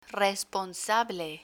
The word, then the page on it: responsable: spanish